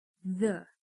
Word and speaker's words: the: Já a palavra the possui uma sonoridade que não temos no português. Basicamente, para pronunciar a maioria das palavras com TH, temos que colocar a língua entre os dentes, meio que assoprar a vogal, que nesse caso seria o a.